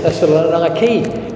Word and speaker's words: Thessaloniki: I think they pronounce it Thessaloniki now in modern Greece.